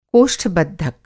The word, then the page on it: कोष्ठ-बद्धक: Hindi